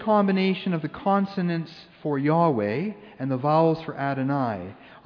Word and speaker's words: Yahweh: That being said, scholars think that it was likely pronounced Yahweh.